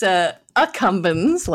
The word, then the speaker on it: accumbens: The nucleus incumbens, if I'm saying that correctly. – It's accumbens, like A-C-C, accumbens, yeah.